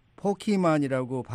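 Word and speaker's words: Pokémon: 이 미국에서는 포키만이라고 발음을 해서 포켓 이런 그 T자가 들어가는 발음을 하지 않는데 아마 이제 한국에서는 네네.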